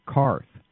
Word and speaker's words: Qarth: I mean, I know I'm throwing Matt's tomatoes all over this episode, but when she's speaking to the scout in Dothraki, she pronounces it correctly. She calls it Qarth.